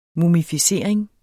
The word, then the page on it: mumificering: mumifiˈseˀʁeŋ